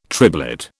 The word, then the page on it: triblet: British English